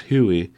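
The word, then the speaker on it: HEUI: the same year that the hydraulic activated electronic controlled unit injector, or Huey, H-E-U-Y for short, pronounced Huey, was first seen on a diesel engine.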